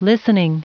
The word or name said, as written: listening